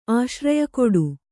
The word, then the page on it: ಆಶ್ರಯಕೊಡು: kannada